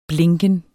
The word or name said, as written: blinken